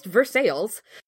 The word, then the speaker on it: Versailles: Because, like, for example, Versailles in Ohio is named after Versailles, but it is pronounced Versailles.